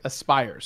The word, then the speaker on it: Aspyr: Pronounced like Aspyr.